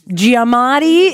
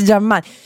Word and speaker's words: Giamatti: che però è Paul Giamatti. Giamatti, e non si dice Giammati.